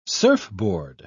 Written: surfboard

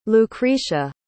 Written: lucretia

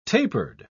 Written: tapered

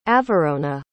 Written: avarona

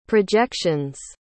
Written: projections

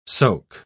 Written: soak

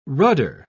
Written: rudder

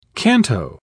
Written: canto

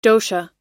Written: dosha

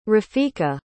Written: rafiqa

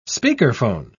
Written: speakerphone

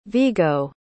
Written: Viggo

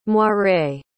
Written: moiré

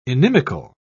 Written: inimical

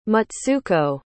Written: mutsuko

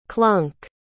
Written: klunk

